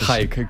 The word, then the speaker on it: Jike: Vamos a escuchar la primera canción de Jique, que se pronuncia así, Jique. Bueno, se pronuncia como te salga de los... – Eso pero es lo afortunadamente que, eso, es fonético, o sea, J-I-K-E. – Podría ser Hike, que también sí. He escuchado por ahí.